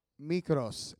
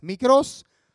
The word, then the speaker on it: Mikros: And that word is spelled Mikros, M-I-K-R-O-S, Mikros. I sound like a real Greek.